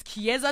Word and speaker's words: Kiesza: K-I-E-S-Z-A. It's Chiesa today.